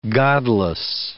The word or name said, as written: godless